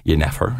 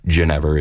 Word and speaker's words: Jenever: So the terminology that the Dutch would use to describe their homemade alcohol, which was Jenever, is the yeah, more correct pronunciation. – Yeah. – But here in North America, Ginever is become the common pronunciation.